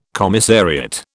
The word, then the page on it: commissariat: British English